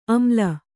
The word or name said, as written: ಅಮ್ಲ